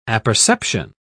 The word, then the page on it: apperception: British English